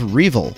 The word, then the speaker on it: REvil: This week, the bounty of $10 million was put on the leadership of Revil, or R-Evil, depending on how you want to pronounce it.